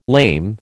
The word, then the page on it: lame: American English